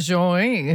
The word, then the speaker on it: Joie: I assume it's pronounced Joey, J-O-I-E.